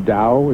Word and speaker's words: Dow: Well, actually, Governor Roland's attorney, I believe, is that guy, the new guy that he got, William Dow, is a D-O-W, which perhaps is going to turn out to be pronounced Dow!